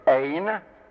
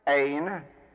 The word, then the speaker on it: Ein: The first of these three terms is Ein, Ein, Ein, and it means no thing or nothing.